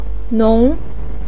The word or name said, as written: nong2